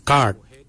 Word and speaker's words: gaad: Yimung is spelled Y-I-M-O-O-N-G, yimung. Yimung is forehead. The next word is head. For head, we say kart, kart. Kart is spelled G-A-A-D, kart.